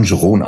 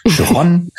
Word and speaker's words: Gereon: Das ist ja die, also das eine ist ja, wie er ausgesprochen wird, Geron, und das andere, das sehen ja jetzt die Zuhörer nicht, Gereon, also Gereon geschrieben und dann Geron ausgesprochen und dann noch mit dem Nachnamen Jörn,